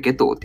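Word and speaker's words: 夏油: えー、ゲトウですよね。そう、ゲトウというそう人物。夏の油って書いてあるゲトウって読むんですね。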